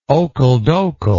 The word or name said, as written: okle dokle